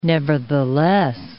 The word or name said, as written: nevertheless